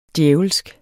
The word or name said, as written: djævelsk